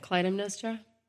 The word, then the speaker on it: Clytemnestra: And when he gets back to Greece after the Trojan War, he finds that his wife, Clymestra, I might be saying that wrong as well. – Is that Clytemnestra? – Yeah, I'm not good at pronouncing these names.